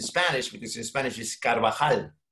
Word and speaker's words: Carvajal: So I just went in there and listened to, it's Carvajal. Okay, then.